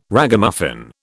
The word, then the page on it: Ragamuffin: British English